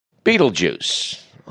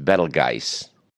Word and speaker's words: Betelgeuse: Betelgeuse, among them, maybe the most famous, although apparently it should be pronounced Betelgeuse, or something along those lines.